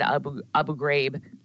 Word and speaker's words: Abu Ghraib: Everyone says Abu on the news. They always said Abu Ghraib, like grape.